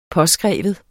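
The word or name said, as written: påskrive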